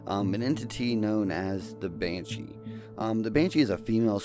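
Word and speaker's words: bean sí: The word Banshee comes from the Irish word, which is B-E-A-N-S-I, but it's pronounced Banshee, and it translates roughly to a woman of the fairy mounds.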